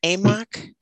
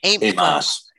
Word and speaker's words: Amos: I wrote you a couple times an email about the book of, is it Amok or Amos? Is it Amok? – Amos. – Amos, yeah. Okay.